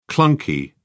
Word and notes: clunky: British English

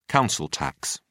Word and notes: council tax: British English

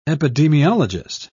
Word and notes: epidemiologist: British English

